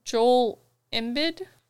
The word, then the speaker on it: Joel Embiid: and Philadelphia 76ers center Joel Embiid. – That's how you say his name.